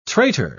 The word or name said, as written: traitor